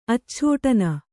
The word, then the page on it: ಆಚ್ಛೋಟನ: ācchōṭana